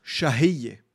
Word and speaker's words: Shahiye: Ja, wie spricht man das Wort aus? – Shahiye.